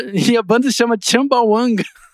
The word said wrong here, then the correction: Chumbawamba: E é chu, é, escreve Chumbawamba. Chumbawamba.